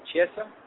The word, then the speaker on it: Chiesa: You'll be able to meet interim welterweight champion Carlos Condit and the new Ultimate Fighter Live finale winner, Michael Chiesa. Am I saying that correctly? – Yes, sir.